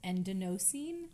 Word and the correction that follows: adenosine: which we didn't go up and look at the pronunciation, but it's, no, adenosine. Eh, a D E N O S I N E.